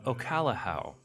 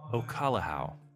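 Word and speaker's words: Okolehao: And then 2.5 ounces of Ocalahau.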